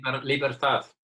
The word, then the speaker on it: Libertad: Yeah, no, but I'm going to resist the temptation, so I'm going to go for Libertad. Is that right? – Yes. – Libertad. Is that the pronunciation? – Libertad. – Libertad. Yeah.